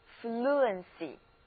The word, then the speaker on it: fluency: 那字母组合 ue 在这里应该念作 ura， ur, fluency.